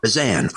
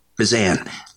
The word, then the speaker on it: Bezan: Is it Bezin or Bezin? – Bezan. – Bezan. All right. Thank you for that. – Just like Began. – All right. Bezan.